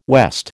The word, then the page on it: west: American English